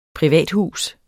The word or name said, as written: privathus